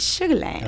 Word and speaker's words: Sugar Land: You got to say it right. It's Sugar Land.